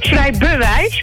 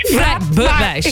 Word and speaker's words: Vrij bewijs: Boek Vrij Wijs. Zeg ik dat goed? – Vrij bewijs. – Vrij bewijs.